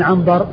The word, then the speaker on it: عنبر: وفرق بين عبثر وعنبر إنما هو بالنقط، وأما الشكل فإنها متفقة، والفرق إنما هو بالنقط والاختلاف في الحروف بين الباء والثاء وبين النون والباء. الباء والثاء، الباء والثاء في الأول عبثر، والنون والباء في الثاني عنبر. فهذان لفظان متفقان في الصورة ولكنهما مختلفان في النطق، عبثر بن القاسم ومحمد بن سواء ابن عنبر السدوسي.